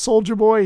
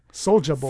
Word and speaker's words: Soulja Boy: He didn't put a sticker on there that says Soulja Boy. He didn't put a logo on it. – Hold on. You said that wrong. It's not Soulja Boy. – Soulja Boy.